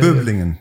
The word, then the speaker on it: Böblingen: Böblingen, if I never said it right. Outside of Stuttgart, there is a... – Böblingen, yeah.